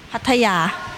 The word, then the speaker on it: Pattaya: Pattaya.